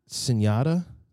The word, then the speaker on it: SVNYATA: Industry, INDVSTRY, Sinyata? – Yep.